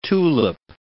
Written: tulip

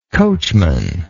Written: coachmanship

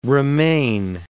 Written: remain

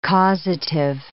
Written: causative

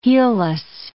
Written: heelless

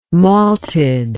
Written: malted

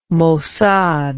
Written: Mossad

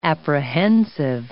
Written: apprehensive

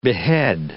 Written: behead

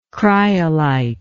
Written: cryolite

